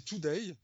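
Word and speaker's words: 2Day: Et celui-ci, donc il s'appelle, euh, on va le prononcer Today. Ça s'écrit le chiffre 2 euh, a y.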